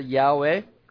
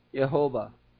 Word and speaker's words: Yahweh: They just simply write YHWH, we pronounce it as Yahweh. Though we do not know the exact pronunciation nor the spelling, you generally would pronounce it Jehovah or Jehovah Yahweh, whichever you choose.